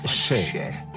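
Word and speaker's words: še: Le mot orge en sumérien se prononce chè.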